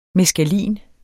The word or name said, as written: meskalin